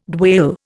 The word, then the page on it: dwale: Indian